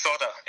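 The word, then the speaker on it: solder: There is an L in the word, isn't there? – But I think it's pronounced sodder in the States, or at least some parts of the States, yeah.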